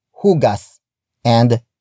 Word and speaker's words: hugasan: If the an suffix is added, the stress shifts to the syllable before the suffix, as shown in hugas and hugasan.